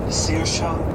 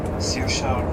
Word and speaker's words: Saoirse: Saoirse Ronan. – Saoirse... – Saoirse Tickets, Sirsha Rondon? I believe you have stolen my heart. – Ja. – Sirsha Rondon. Saoirse Ronan.